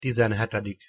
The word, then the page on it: tizenhetedik: Hungarian